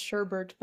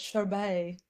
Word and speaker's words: sherbet: I do, fun fact, I do have a friend who's convinced that it's not pronounced sherbert, but sherbet.